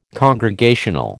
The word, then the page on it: congregational: American English